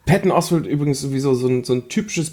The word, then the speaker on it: Patton Oswalt: Patton Oswalt? – Patton Oswalt, ja.